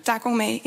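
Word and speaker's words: Da Gong Mei (打工妹): Dat zijn Da Gong Mei. – Ja. – Dat is ook Chinees. Zeg ik dat goed? – Da Gong Mei, inderdaad.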